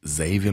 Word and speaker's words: Xavier: Und eigentlich ist es Xavier mit stimmhaftem S. Und da das immer so schwer zu erklären war, hat meine alte Plattenfirma 3P dann immer den Leuten gesagt, es wird ausgesprochen wie der englische Xavier.